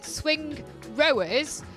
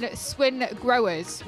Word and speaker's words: Swingrowers: pronounce it Swingrowers. – Ah, there we go. – Yeah, there's the key. Yeah, it's pronounced Swingrowers.